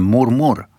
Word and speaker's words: mormor: No i zdążyliście mi napisać, że po szwedzku mormor to się wymawia murmur.